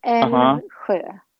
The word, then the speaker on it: Älvsjö: but it's called Älvsjö, Stockholm. – Älvsjö. – Okay. Yes. I can't say, make my mouth do that. So Älvsjö. – So it's almost like an elf, you know, but it's Älvsjö.